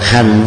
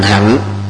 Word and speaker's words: hạnh: Còn hành vô hành hạnh thứ hai đó không nên đọc là hành, về hành là động từ, hạnh là danh từ.